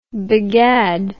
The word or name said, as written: begad